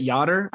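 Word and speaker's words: Jader: I've heard it Yoder. I think Yoder O'Brien is the yeah. – I think that's going to be the best way.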